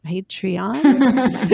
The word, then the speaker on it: Patreon: say it. Patreon? – yeah, I know. Right. You're saying it right.